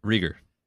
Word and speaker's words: Reagor: Reagor, how do you say it? Reagor or Reagor? – Reagor. – Reagor. Okay, I've been saying it wrong my entire life that I've known about him, which is like 3 months.